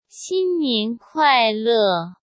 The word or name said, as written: xinnian kuaile